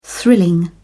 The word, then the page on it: thrilling: British English